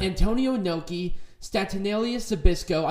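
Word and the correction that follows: Stanislaus Zbyszko: I butchered that, but whatever. – Stanislas Zabisco.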